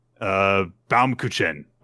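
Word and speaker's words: Baumkuchen: It's everyone with, oh gosh, I always forget how to pronounce this word. It's Bamku. Oh god, it's a hard one. Bamkuchen is how you say it.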